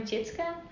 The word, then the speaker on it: Tjitske: Hi, Tjitske. – Hello. – Did I pronounce this right? – Yes. – Yay! Could you shortly introduce yourself? – Yeah. – Main information. – I am Tjitske.